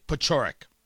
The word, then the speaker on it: Paciorek: Pachoric, P A C I O R E K. Pachoric. That's how you pronounce it.